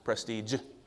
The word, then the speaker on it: prestige: Is prestige or prestige? Help me out, please. What? Prestige. J at the end.